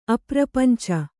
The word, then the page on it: ಅಪ್ರಪಂಚ: aprapañca